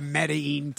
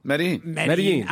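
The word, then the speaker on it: Medellín: I actually have a Medellin t-shirt. – That's the way you should say it right there. Boom. – Medellin.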